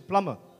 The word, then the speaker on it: plumber: But actually, it's a plumber. It's not plumber. The B is silent.